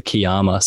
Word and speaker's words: Kiama: Is it K-Y-A? – K-I-A-M-A. K-I-A. Also, yeah, another pronunciation issue people have is the Kiama. So, Kiama.